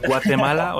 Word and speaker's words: Guatemala: Und das so ausgesprochen, sprechen die das so aus? Guatemala? Oder sprechen ja, die sprechen, die sind schon, legen schon viel Leidenschaft in den Ausspruch ihres eigenen Landes. Also kommt gut hin.